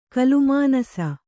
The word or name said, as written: ಕಲ್ಲುಮನಸ್ಸು